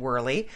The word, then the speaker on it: Wehrley: I'm Susan Wehrle. And that's spelled W-E-H-R-L-E-Y.